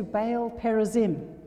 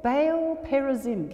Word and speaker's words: Baal Perazim: So David went to Baal Perazim." Everybody say that. Baal Perazim. Get used to it. Baal Perazim. There we go. Different pronunciations, but it's okay. Depends which part of the country you're from. But anyway, "and David defeated them there.